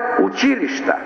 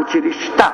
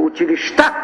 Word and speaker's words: училища: Кодификацията, например, налага да се изговаря училища. Ударение на втората сричка, на И, а не на А, училища. Само че днес, при представенето на становищата, в подкрепа даже на двата законопроекта, ние чухме ударение на последната сричка, училища.